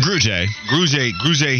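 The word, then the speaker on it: Grugier-Hill: Am I mispronouncing it? – Grugier Hill also had another one in the hole that he could have gotten a tackle for loss.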